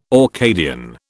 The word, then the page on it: Orcadian: British English